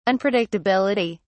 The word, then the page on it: unpredictability: British English